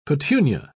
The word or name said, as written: petunia